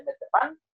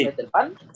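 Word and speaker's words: Metapán: David, él nació en El Paso, Texas, debutó en el Isidro de Metepán. ¿Sí lo pronuncié bien? – Sí. – Metepán.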